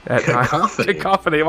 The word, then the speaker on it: cacophony: C-A-C-O-P-H-O-N-Y. Cacophony. – Cacophony. – Why can't I say that word? That's sad. – Maybe a cacophony? – A cacophony? A chicken? – It's a cacophony.